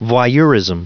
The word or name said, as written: voyeurism